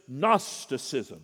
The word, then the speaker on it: Gnosticism: The Bible scholars will tell us that what Peter is fighting against, invading the church, is Gnosticism. Now that's a mouthful of a word. It even begins with a G that you don't pronounce, which that makes it a weird word all the way around.